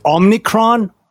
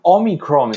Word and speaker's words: Omicron: Omicron seems to be the one that's used the most. – Omni. Uh, but, uh, Omicron. Omni. There's no N at the start.